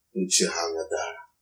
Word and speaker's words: Uchiha Madara: Uchiha Madara.